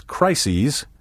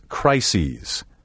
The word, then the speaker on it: crises: As you can hear, the second syllable of the plural form is pronounced ees. Take care not to say this crises when you mean this crisis.